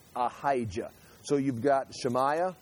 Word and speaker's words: Shemaiah: Give me a pronunciation, Arlen. Shemaiah? Okay, excellent. Okay. Shemaiah.